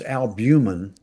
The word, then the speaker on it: albumin: Well, it's albumin. Albumin. How it's pronounced.